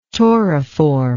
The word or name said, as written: tauriform